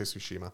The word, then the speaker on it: Tsushima: Tsushima. – Some people say Tsushima. Some people say Tsushima. – I don't know either way. – When the developers and the folks from the island, they pronounce it Tsushima.